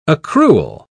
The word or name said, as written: accrual